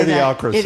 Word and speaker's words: Idiocracy: How do you say Idiocracy. – That? Idiocracy.